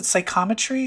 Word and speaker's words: psychometry: she has psychometry? Is that how you say it? – Yeah.